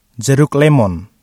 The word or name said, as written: jeruk lemon